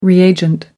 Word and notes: reagent: American English

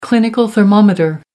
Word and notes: clinical thermometer: American English

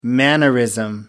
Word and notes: mannerism: American English

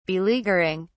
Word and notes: beleaguering: British English

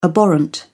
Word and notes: abhorrent: British English

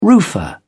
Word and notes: roofer: British English

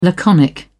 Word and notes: laconic: British English

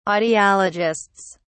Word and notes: audiologists: British English

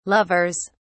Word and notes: lovers: British English

